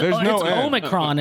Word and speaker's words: Omicron: Yeah, Omicron. Right. I want to say Omarion. – Omicron, but there's no end. – Just say Omarion. – Oh. It's Omicron, isn't it? – Yeah, there's no N, but I want to say Omicron.